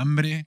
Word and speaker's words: hambre: La hambre con la H que no se pronuncia, que como siempre es muda.